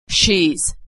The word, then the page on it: shes: z